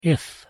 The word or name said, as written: if